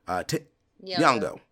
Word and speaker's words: Nyong'o: Nyong'o.